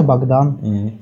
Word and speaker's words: Богдан: Вот а, у вас а, имя Богдан или Богдан, я не знаю, как правильно ударение ставить. – А, не, у меня, у меня обычное русское имя Богдан.